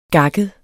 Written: gakket

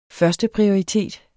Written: førsteprioritet

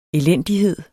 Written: elendighed

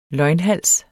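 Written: LØGNHALSE

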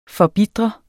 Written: forbitre

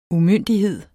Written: umyndighed

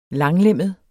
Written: langlemmet

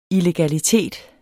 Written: illegalitet

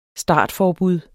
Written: startforbud

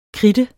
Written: kridte

